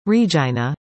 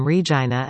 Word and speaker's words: Regina: Among the slogans used by the campaign online and on social media were such taglines as, "The city that rhymes with fun," and, "Show us your Regina."